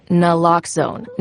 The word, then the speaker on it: Naloxone: For the record, it's Naloxone. Nuh, like, is Naloxone addictive? Nuh-uh. Lox, like Naloxone keeps humans safe, like locks keep bikes safe. And own, like Naloxone can save lives in our very own communities.